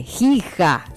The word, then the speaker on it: Giga: Se escribe con G. Giga. Escribe. Giga. Mucho. Nosotros decimos un giga.